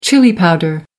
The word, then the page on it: chili powder: American English